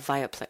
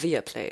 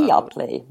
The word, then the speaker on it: Viaplay: Und ich gehe auch mal stark davon aus, also für Interessierte jetzt da draußen, ne, Viaplay, ich sage immer Viaplay, aber ich glaube Viaplay ist die Aussprache. – Viaplay, ja, Viaplay.